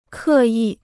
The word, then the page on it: 刻意: kè yì